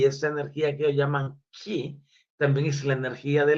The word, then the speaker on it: Ch'i: Esa energía a la que ellos le nombran la energía ki. Se escribe CH apóstrofe I.